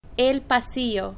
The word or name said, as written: el pasillo